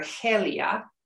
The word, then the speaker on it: Gelia: Suintila was definitely a noble Goth from a prominent family because he and his brother Helia, which is spelled G-E-L-I-A, by the way.